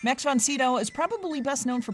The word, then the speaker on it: von Sydow: And I say, von Sydow.